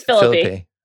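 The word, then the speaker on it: Phillippe: It's Philippe. – I like calling Ryan Philippe. – It's kind of like Los Feliz. It's Philippe, you know?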